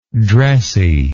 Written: dressiness

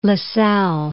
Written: La Salle

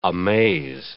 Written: amaze

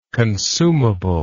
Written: consumability

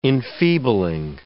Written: enfeebling

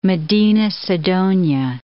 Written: Medina-Sidonia

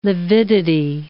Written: lividity

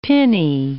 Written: pinny

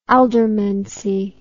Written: aldermancy

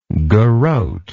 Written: garotter

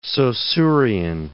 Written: Saussurian

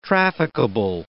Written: trafficable

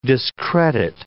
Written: discredit

